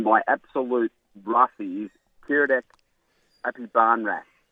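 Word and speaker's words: Aphibarnrat: I did, Apibarnrat.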